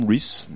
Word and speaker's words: Rhys: C'est qu'en gros, il a vu ce monsieur, donc pas Tom Rice, mais Tom Rhys. Voilà, ça c'est la prononciation anglaise, excusez-moi.